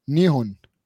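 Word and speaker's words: نيهون (日本): انت عارف بقى له آه اليابان بيكتبوا الدوله اسم الدوله نيبون ان اي بي بي او ان انت عارف بقى بتتنطق ازاي؟ مم. نيون نيهون نيهون اوكي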